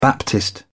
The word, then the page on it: baptist: British English